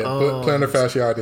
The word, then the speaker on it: plantar fasciitis: They just announced that TJ Warren got plantar fasciitis. I think is how you pronounce the injury. – Yeah, plantar fasciitis.